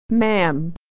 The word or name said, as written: ma'am